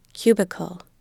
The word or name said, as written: cubicle